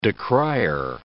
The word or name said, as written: decrier